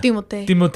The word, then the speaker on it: Timothée: I've seen Timothée... – Timothée. – I've seen him pull some. I mean, that is how you say it. – I know, it's so funny. – I mean, they say it prettier. It's like Timothée.